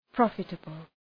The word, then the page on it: profitable: 'prɒfıtəbəl